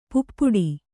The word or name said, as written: ಪೈಪಾಡು